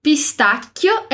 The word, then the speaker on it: pistacchio: Pistacchio é escrito com dois Cs. Então, a gente vai fazer aquela pausa na pronúncia aqui. Pistacchio.